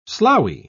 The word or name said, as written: sloughy